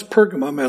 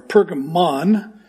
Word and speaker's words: Pergamum: The name Pergamum is usually, you'll encounter it in three ways. One is Pergamum, as I'm pronouncing it today, or Pergamon, like Pokemon, or Pergamos.